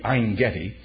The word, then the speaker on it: Ein-Gedi: As a matter of fact, at En-Gedi, Ein-Gedi, Ein in Hebrew is the spring. That's what the word means. A correct pronunciation will be Ein-Gedi.